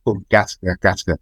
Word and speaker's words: Gattaca: Gattaca? Gattaca? Is that correct? – Gattaca, yeah.